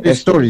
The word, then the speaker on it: Story: Eh, es así que se pronuncia ¿no? – Story, story, story, story.